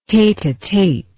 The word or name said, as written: tete-a-tete